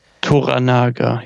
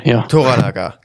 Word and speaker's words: Toranaga: Wir begleiten aber diesen Lord Toro Naga. – Toranaga, Toranaga, genau. So ist es ausgesprochen, richtig.